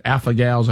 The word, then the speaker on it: alpha-gal: And the fact that some people who have had the, is it alpha-gals, how you say it? – Alpha-gal, yep.